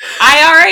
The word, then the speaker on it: IRA: RIA. – I do. IRA is how you say it. – No, you keep saying RIA. – Oh, IRA. I'm sorry.